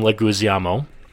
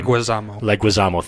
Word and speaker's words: Leguizamo: John Leguizamo. I probably mispronounced that last name. – Leguizamo, thank you.